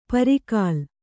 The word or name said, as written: ಪರಕಾಲ